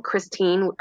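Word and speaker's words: Kristine: So if you go to Amazon, you can either type in my name, Christine Irwin. It's spelled with a K, K-R-I-S-T-I-N-E. And last name is Irwin, I-R-W-I-N.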